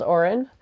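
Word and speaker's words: Òran: It's pronounced like it's spelled Oren.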